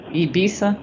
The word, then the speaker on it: Ibiza: Ibiza? – Yes. – So Ibiza, I don't know who Addison is, but Addison had a bachelor party, and you went to Ibiza.